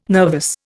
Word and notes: nervous: Indian

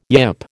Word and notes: yip: American English

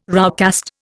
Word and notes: roughcast: Indian

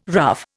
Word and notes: rough: Indian

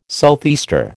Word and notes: southeaster: American English

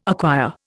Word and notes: acquire: Indian

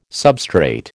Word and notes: substrate: American English

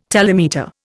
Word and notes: telemeter: Indian

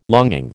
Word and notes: longing: American English

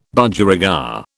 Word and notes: Budgerigar: British English

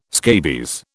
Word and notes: scabies: British English

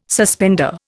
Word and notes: suspender: Indian

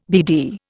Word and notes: BD: Indian

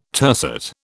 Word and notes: tercet: British English

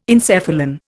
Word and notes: encephalon: Indian

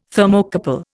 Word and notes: Thermocouple: Indian

